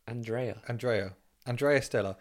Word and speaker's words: Andrea Stella: So Andrei Stella. – No, say his name again. – Andrei Stella. Andrei. Andrea Stella. There we go.